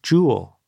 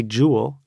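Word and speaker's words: Joule: Well, let's get back to this idea of James Prescott Jowell or Jowell.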